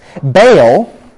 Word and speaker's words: Baal: Baal is actually how you'd pronounce it.